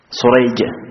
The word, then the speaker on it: سريج: هنا أسماء الآباء اتفقت، لكن أسماء الرواة اتفقت خطا واختلفت لفظا، فسريج وشريح نفس الحروف باختلاف النقاط، هذه تنطق شريح وهذه تنطق سريج، هذه بالشين المعجمة وهذه بالسين المهملة، وهذه بالحاء المهملة الأولى شريح، والثانية بالجيم المعجمة أي التي عليها نقطة.